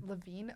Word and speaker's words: Lavigne: Levine.